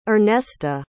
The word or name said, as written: Ernesta